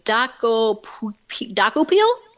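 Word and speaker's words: Dokoupil: How do you say this guy's name? Daco Peel. We'll go with Daco Peel.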